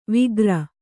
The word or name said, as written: ವಿಗ್ರ